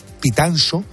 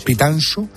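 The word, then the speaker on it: Pitanxo: El buque arrastrero gallego Villa de Pitanso, se dice así en gallego, ¿no? – Se dice así. – Pitanso se hundía frente a las costas de Terranova y Labrador en Canadá.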